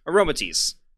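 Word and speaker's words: Aromatisse: Aromatisti? Yeah, I don't know how to pronounce that. It has aroma in it. Aroma and then tease. Aromatis.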